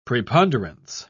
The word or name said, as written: preponderance